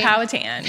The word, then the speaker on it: Powhatan: Powhatan.